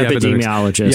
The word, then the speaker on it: epidemiologist: Epidemiologist, whatever, the epidemic person, you know, the people who study epidemiologists.